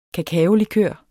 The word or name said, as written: kakaolikør